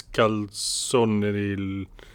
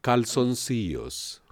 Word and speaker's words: calzoncillos: Mis calzoneril. – Calzoncillos.